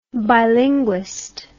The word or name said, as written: bilinguist